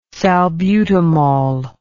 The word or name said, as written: salbutamol